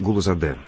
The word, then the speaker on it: Гулузаде: Галузаде, если не ошибаюсь, да? Правильно сказал? – Да, ну, немножко вы говорили именно с московским, я бы так сказал, акцентом. Вафа Мирзоевич Гулузаде, правильно? – Ну, ради бога, извините, пожалуйста, не хотел обидеть.